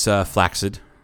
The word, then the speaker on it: flaccid: And when it's flaccid, which, yes, that's the proper pronunciation. – Flaccid. – It's the proper pronunciation.